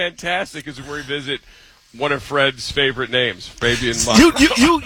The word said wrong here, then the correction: Moreau: Yeah, Fabian Moreau is the correct way.